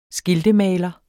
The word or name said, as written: skiltemaler